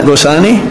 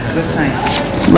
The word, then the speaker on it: Gosain: The N is not pronounced. Nai, Gosai. – Oh, really? – You merge the N and I to make a Gosain.